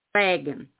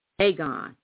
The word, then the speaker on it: Dagon: Then they were a coastal people and worshiped Dagon. I'm sorry, I pronounced that wrong. Dagon, and worshiped Dagon.